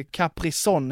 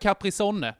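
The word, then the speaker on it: Capri-Sonne: De har ju döpt om det till Caprison nu för att ingen fattar hur man uttalar sonne. Eller alltså, son ska det ju heta. Ja. Men det stavades sonne.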